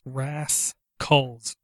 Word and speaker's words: Raskulls: Rascals Online Unreleased, but it's spelled R-A-S-K-U-L-L-S, Rascals, like skull, you know.